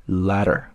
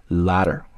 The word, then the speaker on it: latter: 啊，再来往后看，in the latter category，说在后者的类别当中啊，category 就是类别，然后前面这个 latter 注意一下它的发音啊，中间这个 a 字母呢发的是一个梅花音啊，a letter，和信封的那个词发音是不同的啊。信封发作 letter，而这个词是 ladder。